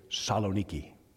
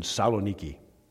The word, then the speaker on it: Saloniki: They say Saloniki. They don't say that first part, so I'm going to say it like the natives do. In Saloniki.